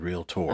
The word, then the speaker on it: realtor: No, I said realtor. – Okay, I had to make sure you said it right. – I even put the emphasis on the tour part.